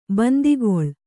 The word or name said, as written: ಬಾಂದುಕಲ್ಲು